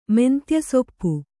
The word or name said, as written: ಮೆಂತ್ಯಸೊಪ್ಪು